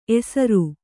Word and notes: ಎಸರು: esaru

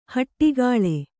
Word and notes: ಹೊಡೆಗೊಳು: hoḍegoḷu

